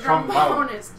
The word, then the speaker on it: trombonist: You gotta emphasize the bone, though. That's what it is. The trombonist.